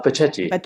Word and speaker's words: bacetti: is it bassetti am i saying that right uh, Bacetti.